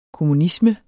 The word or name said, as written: kommunisme